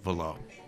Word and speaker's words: Vallot: And it is indeed Lothar Velot. – Spelled V-A-L-L-O-T. – T. – Silent. – Which is silent. – Silent T. – Yes, it's French.